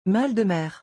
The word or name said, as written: mal de mer